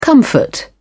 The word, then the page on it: comfort: British English